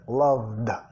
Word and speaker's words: loved: I've loved, nota, este caso, de, loved, la E de terminación pronunciada como D.